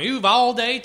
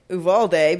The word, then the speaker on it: Uvalde: He's from Uvalde, although he pronounced it, you know, Southern Texas style because it's Matthew McConaughey. – Well, that means that's the real way you pronounce it. – He says, I'm from Uvalde, Texas. – Well, that's the correct way to pronounce it.